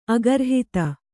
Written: ಅಗರ್ಹಿತ

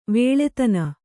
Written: ವಾಳಾದಾನ

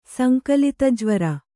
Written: ಸಂಕಲಿತಜ್ವರ

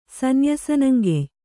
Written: ಸನ್ಯಾಸನಂಗೆಯ್

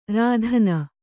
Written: ರೋದನಿ

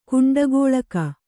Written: ಕುಂಡಗೋಲಕ

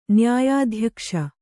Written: ನ್ಯಾಯಾಧ್ಯಕ್ಷ

